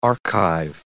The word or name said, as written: archive